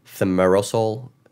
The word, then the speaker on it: thimerosal: Another thing, what they were pushing is they said this preservative called thimerosal, is how you say it.